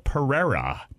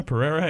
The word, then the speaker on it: Pereira: Pereira? And what did I say? It's because you challenged it. He's like, that one's got two rolling R's in it.